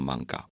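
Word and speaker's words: 艋舺: 用 manga 这个发音，它是平埔族的语言，它不是汉族的语言。平埔族的语言称 manga 是说，平埔族当时的少数民族有一种船，独木舟，这种船叫做 manga。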